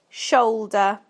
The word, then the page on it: shoulder: British English